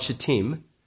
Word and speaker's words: Shittim: You know, it was early morning when Israel gathered at a rally point called Shittim. That's the right way to pronounce it, by the way, shittim.